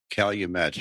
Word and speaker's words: Calumet: Did I pronounce that right? – Calumet. You're not a Midwesterner.